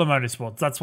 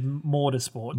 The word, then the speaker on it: Motorsport: No, it's just Forza Motorsport. Earlier you said Mortarsport, but you know, if you want to change up the pronunciation every now and then, Ash, you do you.